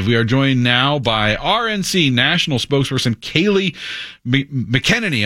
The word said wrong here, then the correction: McEnany: It's McEnany. – McEnany.